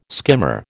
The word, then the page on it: skimmer: American English